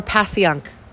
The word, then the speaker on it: Passyunk: Is it pashunk or Pasyank? – it's it's pronounced Pashunk but for those looking up the website, I'm saying Passyunk.